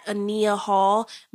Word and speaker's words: Ania Hall: So you can follow me on Instagram at Ania Hall. My name is spelled A-N-I-A-H-A-L-L.